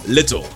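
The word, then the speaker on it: Lyttle: Little. It's pronounced Little.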